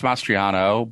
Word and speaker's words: Mastriano: Is it Mastriani? Is that how you say it, the Republican candidate? – I think it's Mastriano, but yeah.